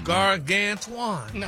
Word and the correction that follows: gargantuan: No. No, sir. – Maybe you should just make some more videos with you with different clothes on with Stephanie Mills music, like you've been doing. – Look at the breakdown of the word. – It's pronounced gargantuan.